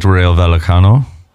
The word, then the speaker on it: Rayo Vallecano: Valencia double chance at Rayo Vallecano.